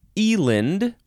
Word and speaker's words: eland: Next is Eland or Eland. Eland, Eland. And it is spelled E-L-A-N-D, just like it sounds.